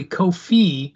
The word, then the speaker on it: Kofi: Well, you love Kobe so much that you forgot that his name is really Kofi, not Kobe.